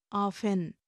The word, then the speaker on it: often: Let us see some other word like O-F-T-E-N. In India, we follow the received pronunciation, so we say often. Then there is also pronunciation like often, mostly used in USA.